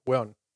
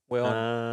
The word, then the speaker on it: weón: El, el weón realmente es como, como de un solo sonido, como una sola sílaba. Weón. Weón. Weón. – Hueón.